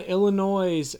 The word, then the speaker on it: Illinois: 116, we have Illinois against Drexel. – Love how you always pronounce the S in Illinois.